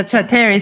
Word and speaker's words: Sateri: It's like the chili pizza, Sateri.